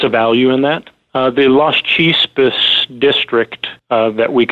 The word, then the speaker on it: Las Chispas: And you have some pretty significant additional drill results worth talking at, and I believe I pronounced this, Las Chispas. – That's correct.